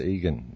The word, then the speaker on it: Egan: It's Max Egan. It's spelt Egan, but it's pronounced Egan.